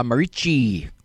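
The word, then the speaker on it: Merici: I want to thank all the folks at St. Angela Marici, pronouncing it the Italian way, or the way I was taught to pronounce it in Latin when I took Latin in school in Missouri City, Texas.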